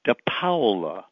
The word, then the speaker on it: DePaola: like T-O-M-M-Y. And my last name is DePaola, not DePaola, even though there's an A before the O. It's an Italian name, and Paola is a town in Calabria that my grandfather came from. And if you think of Paola as being P-O-W-L-A, that's how you pronounce the Paola part.